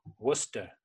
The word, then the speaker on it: Worcester: Worcester, which is Worcester. Spelled like, for people not from Boston, it's Worcester, right? – Exactly. Well, that's like everything in this area. Nothing sounds like the way it's spelled.